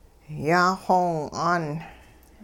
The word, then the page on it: yaaxoow'aan: hupa